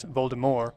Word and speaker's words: Voldemort: I'm the only person in the world who pronounces Voldemort as Voldemort. The T is silent.